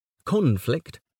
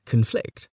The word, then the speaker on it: conflict: For example, the noun is conflict, but the verb is conflict.